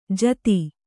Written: ಜತಿ

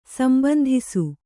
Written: ಸಂಬಂಧಿಸು

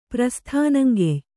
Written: ಪ್ರಸ್ಥಾನಂಗೆಯ್

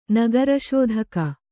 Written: ನಗರಶೋಧಕ

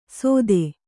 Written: ಸೊಥೆ

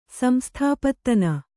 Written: ಸಂಸ್ಥಾಪತ್ತನ

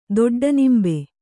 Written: ದೊಡ್ಡನಿಂಬೆ

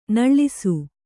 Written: ನೋಳಿಸು

